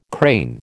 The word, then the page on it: crane: American English